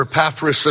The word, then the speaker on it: Epaphras: I want to be like Epaphras, or Epaphras, as some have pronounced it.